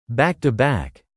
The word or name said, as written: back-to-back